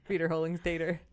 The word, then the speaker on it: Wiederholungstäter: That's like the tater. American way to say that word. Wiederholungstäter.